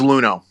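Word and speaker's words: Luhnow: How did you say it? Lunau, is that right? – It's Luno.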